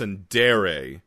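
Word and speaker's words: tsundere: Oh, wait, so you're saying this is an entire world where everyone is a Sunder? – I think that's pronounced Sundere. – Yeah, it is.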